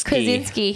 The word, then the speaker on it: Krasinski: And I think an example, an actor who was a hilarious dude, John Krasinski. I don't know how to ever pronounce his name. – Krasinski.